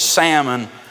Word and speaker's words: Salmon: You know, the Bible tells us in Matthew chapter number 1, in the genealogy of the Lord Jesus Christ, it says, and Salmon, that's how I pronounce it, like the fish, and Salmon begat Boaz of Rahab.